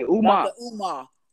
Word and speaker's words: Umar: Dr. Amu. What his name? Am I saying it wrong? Forgive me if I'm saying it wrong. – You say Umar.